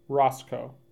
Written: Roscoe

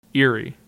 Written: Erie

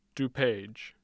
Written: dupage

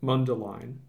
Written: Mundelein

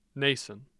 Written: nason

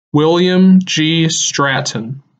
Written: William G. Stratton

